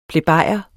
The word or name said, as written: plebejer